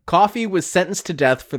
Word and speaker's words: Coffey: But when the movie starts, a third inmate arrives, a very large man named John Coffey, pronounced like the drink but not spelled the same, played by Michael Clarke Duncan.